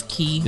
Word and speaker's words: Cay: It's Key. – It's Key. That's right. I always get confused. – It's the way they spell it, K, you know? But it's Key.